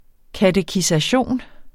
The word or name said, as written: KATEKISATIONERNES